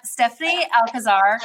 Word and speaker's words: Stephannie Alcazar: We have Stephannie Alcazar.